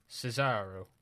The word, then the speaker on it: Cesaro: Roderick Strong versus Cesaro. That's how you pronounce his name.